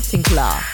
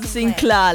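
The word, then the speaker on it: Sinclar: That's how you say it, yeah? Sinclair. – Okay. – Sinclair. There's no I, man.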